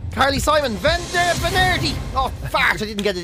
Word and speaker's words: venerdì: Venerdì is how you say it, to be honest with you.